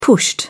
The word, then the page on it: pushed: British English